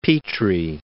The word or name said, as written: Petrie